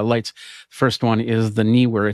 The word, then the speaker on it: Neewer: Yeah, that's how you say it, Neewer, uh, and they're they're putting together an app that, and uh, you can also get the Stream Deck plugin download.